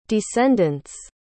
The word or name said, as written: descendant's